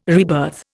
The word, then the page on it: rebirth: Indian